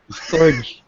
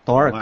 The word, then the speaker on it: Torque: Torge. – Torge. – Sinisira niyo yung brand eh. Torque.